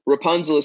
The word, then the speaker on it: Rapunzl: So Rapunzl is spelled R A P U N Z L. It's like the fairy tale, except there is no E.